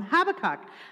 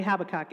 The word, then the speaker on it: Habakkuk: So the first thing I did was find out that it's not actually Habakkuk. It's Habakkuk.